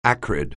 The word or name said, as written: acrid